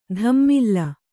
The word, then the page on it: ದಮ್ಮಿಲ್ಲ: Kannada